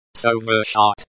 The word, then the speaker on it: совершать: Pronounced, совершать, so, as in, so, ver, as in, very, шат, as in, шатter, with the, t, sound very light.